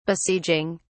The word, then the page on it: besieging: British English